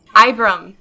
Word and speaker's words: Ibram: That is Abram. Doing it again. Ibram? – Ibram.